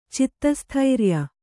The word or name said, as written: ಚಿತ್ತಸ್ಥೈರ್ಯ